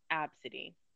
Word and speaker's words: ABCDE: So, she boards first, and the lady at the counter is like, your daughter's name is ABCDE? And she was like, yes, but it's pronounced Absidy.